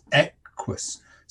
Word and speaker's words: equus: In the Latin word equus, the stress falls on the first syllable, so it is equus. So that it's dumdi. So that's a trochee.